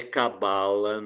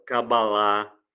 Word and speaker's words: Kabbalah: Não é cabala, não é livro de autoajuda, mas que diz respeito à interpretação bíblica do hebraico, a cabalá, essa é a pronúncia, aponta para o número 3 como um número simbólico.